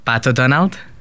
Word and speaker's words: Pato Donald: Pato Donald.